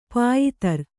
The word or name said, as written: ಪಯಿತ್ರ